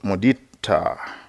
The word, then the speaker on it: Mudita: so So this afternoon I'm going to introduce one of the fourth Brahma Vihara is called Mudita. So those who are interested in Pali, each Pali word for the four Brahma Vihars is a long A. It ends with a, a, the A is long.